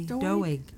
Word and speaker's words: Doeg: So Saul turned to, how do you say his name, Doeg? – That's what I'd say. Doeg.